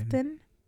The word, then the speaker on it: often: Often? Often? – Yeah. – Often? – Yeah, you don't say the T. The T is silent.